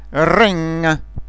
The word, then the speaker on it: ring: Când se termină cuvântul NG, G-ul nu se aude. Este ca și cum ai vrea să-l pronunți și cineva, la un moment dat, te oprește. Sing, ring.